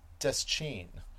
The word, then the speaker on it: Deschain: So instead of it just being his father was dressed in black jeans and a blue work shirt, Stephen Duchesne. So we've got, in the audio, he pronounces Deschain. – Deschain. Oh, I was, I never put the accent that way. I always say just Deschain.